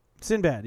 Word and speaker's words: Sinbad: Am I saying it right? Sinbad? – Sinbad, yes.